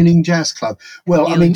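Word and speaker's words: Ealing: So his first public performance as a member of the group, I read, was at the Erling Jazz Club. Is that the right way to say that? – Yes. That's the Erling Jazz Club.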